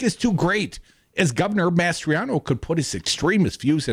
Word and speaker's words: Mastriano: Did I say that right, Dom? – Mastriano. – Mastriano. Tell me, Sicilian, how do you say it? Mastriano.